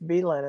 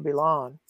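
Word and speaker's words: Billund: You've taught me that it's Beeland and not Beelon.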